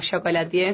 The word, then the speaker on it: chocolatier: Es de, bueno, es uno de los bombones de mi línea, que es Chocolatier, o Chocolatier, si queremos pronunciar mejor el francés.